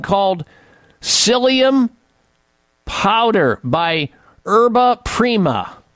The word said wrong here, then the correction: Yerba Prima: Yerba Prima is spelled Y-E-R-B-A Prima, P-R-I-M-A.